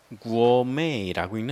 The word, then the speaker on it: gourmet: 미식가, gourmet라고 읽는데요. 자, t에다 밑줄 긋고 무금이라고 쓰십시오. t는 읽지 않습니다. 프랑스 말에서 왔기 때문이죠.